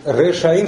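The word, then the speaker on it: Решаим (ראשים): Решаим с редукцией. Раша. – С двумя камазами. Решаим, шва и камаз. Потому что здесь присоединилось окончание, ударение сместилось на один слог. Как давар, деварим, да? Там редукция происходит.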